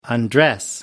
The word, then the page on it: undress: American English